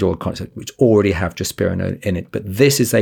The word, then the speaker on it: drospirenone: Yeah, so this is drospirenone, or SLYND, S-L-Y-N-D.